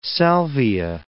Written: salvia

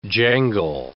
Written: jangle

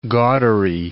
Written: gaudery

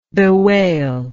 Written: bewailment

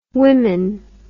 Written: wimyn

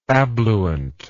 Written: abluent

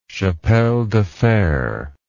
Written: chapel de fer

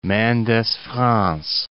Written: Mendes-France

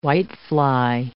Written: whitefly

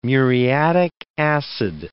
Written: muriatic acid

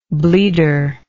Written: bleeder

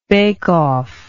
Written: bake off

